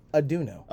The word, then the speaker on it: Aduonno: It's Aduonno.